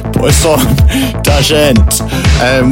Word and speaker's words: Poisson d'Argent: This is Poisson. No, it's not. It's Poisson d'Argent, which I understand translates to silverfish.